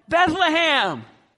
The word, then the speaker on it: Bethlehem: Bethlehem! You even pronounced it right.